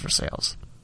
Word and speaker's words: Versailles: And Versailles is spelled like Versailles, but it's pronounced Versailles. Don't ask.